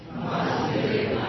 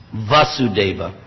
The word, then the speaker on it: Vasudeva: Vasudeva. – Yes, that's a short A. Recently, someone pointed that out to me. Even I was pronouncing it wrong. Vasudeva.